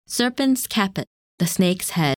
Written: Serpens Caput

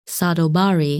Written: Sadal Bari

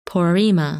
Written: Porrima

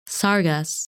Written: Sargas

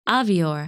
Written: Avior

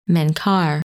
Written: Menkar

